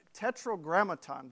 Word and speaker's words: Tetragrammaton: That is an English translation of a word that has become known as the tetragrammaton. I gotta make sure that I said it right. I gotta double check here. Tetragrammaton, yeah.